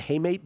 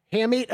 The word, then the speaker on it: hamate: Broken, how do you pronounce it, haymate bone? – Hamate, I believe. – I think it's hay. – Oh, okay. – I've heard it pronounced hamate.